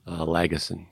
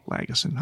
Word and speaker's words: Lagesson: You know, the likes of William Lagesson or how do you pronounce his last name? – Lagesson. – Lagesson. That was close. It was a G.